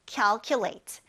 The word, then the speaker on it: calculate: calculate. 여기에서 cal 처음에 시작하는 이 발음이 cal, 약간 이야, cal에 가까워요. 그래서 calculate 하지 말고 calculate 이렇게 발음하시면 됩니다.